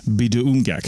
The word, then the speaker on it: Pituamkek: It looks like Pitumkek, but it's pronounced Biduumgek.